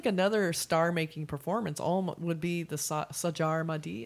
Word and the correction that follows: Sanjar Madi: Is how you say? it. – Yeah, Sanjar Madi.